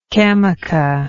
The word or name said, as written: camaca